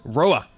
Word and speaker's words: Roewe: So the Chinese company now calls it Roa. – Which is because they couldn't call it Rover. – It's true. – So it's called Roa with a W. – And it's spelt some... It's the spelling's quite unusual, isn't it? – Yeah, R-O-E-W-E.